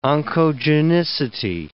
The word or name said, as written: oncogenicity